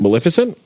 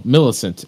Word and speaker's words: Millicent: I don't know how to say her name. Millicent Simmons? – Maleficent? – No, Millicent. MILLICENT. – That would be Millicent.